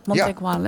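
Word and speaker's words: Mont Aigoual: over de Montegual. Zeg ik het goed? – Montegual, ja.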